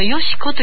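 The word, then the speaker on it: 徳子: この三浦義子さん、この義子というの、徳という書いて義子と読むんですね。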